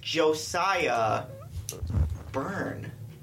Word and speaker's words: Josiah Burn: It's Josiah Burn. – I say burn. – No, you said burns, and he gets really mad when you add the S.